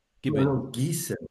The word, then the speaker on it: Gießen: Now, excuse my pronunciation. Gießen, is that correct? Have I pronounced that correctly? – Gießen. – Okay.